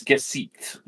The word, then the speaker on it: Gesicht: As we then cut over to our main character, Gesicht. I'm probably pronouncing that name wrong. – I believe it's Gesicht, but I'll look it up. – Yeah, it's German for face.